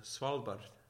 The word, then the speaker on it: Svalbard: Okay, so up in Svalbard, am I saying that right? – No. So it means cold shores. Svalbard.